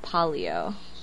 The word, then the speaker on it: Palio: I'm guessing it's polio. – It's like patio, but the T is an L.